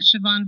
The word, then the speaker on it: Chevon: The fundraiser is on my Twitter, which is at Siobhan Velez. It's spelled C-H-E-V-O-N, last name V-E-L-E-Z.